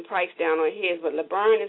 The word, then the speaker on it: LeBron: Oh, LeBron.